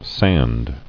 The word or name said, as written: Sanded